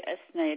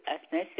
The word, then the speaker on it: Hasnae: Madame Lagzaoui Asnaï. Lagzaoui, c'est L-A-G-H-Z-A-O-U-I. Lagzaoui Asnaï, c'est H-A-S-N-A-E.